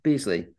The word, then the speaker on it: Beasley: And do I have the last name, the pronunciation? – Beasley.